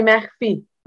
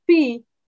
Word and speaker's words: Mechfi: It's you can say Mechfi. Mechfi, you can call it Mechfi. It's very hard to say the ch. A lot of Israelis there. We have the head the throat there.